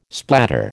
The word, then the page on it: splatter: American English